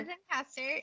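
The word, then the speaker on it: Zencastr: Am I even saying that right? Zencaster? – Yeah, Zencaster. Yeah, you're saying it right.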